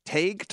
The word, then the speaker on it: Tage: Tage Thompson? How do you say that? T-A-G-E. Yeah. What is that? Which one? – Tage Thompson.